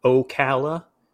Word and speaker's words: Ocala: I had to look up the pronunciation to make sure that I didn't call it Ocala. Ocala is the proper pronunciation.